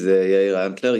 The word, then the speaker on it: Yair Antler: My name is Yair Antler.